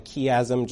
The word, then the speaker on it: chiasm: You know, sometimes when I'm preaching through the Psalms or both the Old and New Testament, I talk about what's called a chiasm. I think Ron used to call it a chiasm, just different way of pronouncing it.